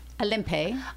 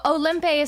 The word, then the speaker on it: Olympe: How do you pronounce her name? Olympe? – Yes. Olympe is how we're doing it in the play. I think if you were truly speaking French, you'd say Olympe de Gouge.